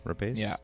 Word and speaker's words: Rapace: Rapace.